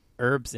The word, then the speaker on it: herb: I think herb with a silent H is kind of a little fancier. – Mm. – And herb with a solid H sound is a little bit more, you know, like kind of rough and ready American style.